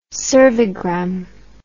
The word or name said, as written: cervigram